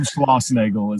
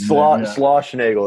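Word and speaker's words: Schlossnagle: Jim Schlossnagel is Schlossnagel.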